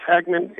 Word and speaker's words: Hagman: Sure, my name is Kurt, C-U-R-T, last name is Hagman, H-A-G-M-A-N.